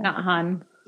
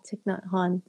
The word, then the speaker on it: Thich Nhat Hanh: How do you say his name? – Thich Nhat Hanh. – Yeah, Thich Nhat Hanh.